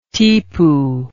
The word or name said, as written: tipu